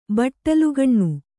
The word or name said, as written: ಬಟ್ಟಲುಗಣ್ಣು